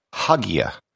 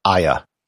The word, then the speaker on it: Hagia: If you had no background and were just a standard English speaker, you might pronounce it Hagia. However, that has never really been how it's been pronounced. There's also Hagia, Hagia with the H silent. The standard pronunciation in what I'll be using in this episode is Aya. This would be as if it were spelled A-Y-A, which is actually how it's spelled in Turkish.